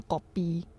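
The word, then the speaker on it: kopi: 嗯，就比如说，呃，有一些他们就是以福建话发音，比如说其中最普遍、最多人点的是咖啡，我们是叫狗逼，就是因为福建话是狗逼嘛，然后就指的是，呃，诶，福建话是咖逼嘛，对，可是我们那边是叫狗逼，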